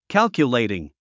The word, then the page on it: calculating: kǽlkjulèitiŋ; キャルキュレイティン